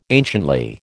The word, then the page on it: anciently: American English